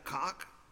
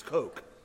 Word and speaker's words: Koch: Her name is Christina Koch. That's right. I've been saying it wrong all day. It's Coke. It's like C-O-K-E, but she spells it K-O-C-H.